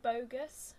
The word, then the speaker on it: Boggess: Well, I thought it was going to be more like Burgess, but not. But I listened to someone say it in an interview, because I didn't want to say it wrong. And it's like, it's actually like Bogus.